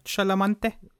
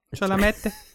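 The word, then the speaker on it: Chalamet: Timothy Chalamante. – Chalamette.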